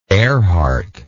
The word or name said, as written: Erhardt